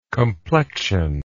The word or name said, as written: complexionally